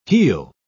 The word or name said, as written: heal